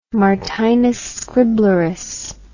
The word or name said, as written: martinus scriblerus